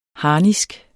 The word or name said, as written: harnisk